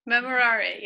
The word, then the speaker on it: Memorare: It also just reminds me of the, I love praying the Memorare. I never say it right. – Memorare, yeah.